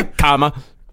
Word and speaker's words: Kama Sutra: The Black Pearl sounds like a Karma, a Kama Sutra. Karma Sutra? – Kama.